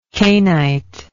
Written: cainite

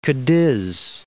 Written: Cadiz

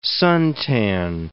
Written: suntan

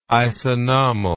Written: isanomal

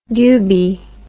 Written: dubee

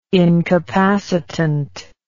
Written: incapacitant

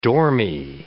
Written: dormy

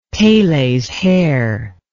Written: Pele's hair

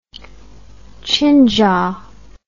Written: chinjaw

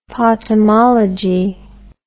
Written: potamology

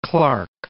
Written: Clark Fork

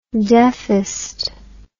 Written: deffest

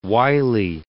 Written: Wylie

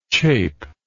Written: chapeless

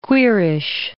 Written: queerish

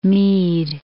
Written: Meade

